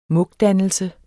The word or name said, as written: mugdannelse